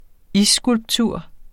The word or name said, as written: isskulptur